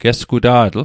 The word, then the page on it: gesguta'tl: Mi'gmaq